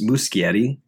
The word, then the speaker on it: Muschietti: The um, part, part of the research that I found, uh, Andy, which another thing I wanted to add, uh, it's Muschietti. Holy shit. I've literally never pronounced that right.